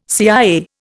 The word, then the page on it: CIA: Indian